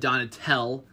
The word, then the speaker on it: Donatell: I was right that it's pronounced one, Ed Donatell, and Donatella is involved with Versace.